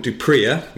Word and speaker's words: du Preez: One, Robert Dupria, which I've been informed is the correct way of pronouncing his surname, who scored 20 points, six penalties and a conversion on his Premiership debut last weekend.